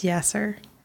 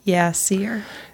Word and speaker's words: Yaser: And I'm sorry that I mispronounced it. It's Yaser. – Yaser. – Not Yasir.